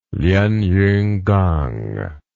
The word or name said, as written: lienyunkang